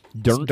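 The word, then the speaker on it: Dirnt: So bassist Mike Dirt, or Durnt, how do you say Durnt? – Durnt. Durnt. – Anyway,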